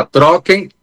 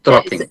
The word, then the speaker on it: Trocken: Trocken, T-R-O-K-E-N. Falei certo? É isso? Trocken. – C-K-E-N, isso. – C-K-E-N.